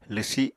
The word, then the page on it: lwsi: apatani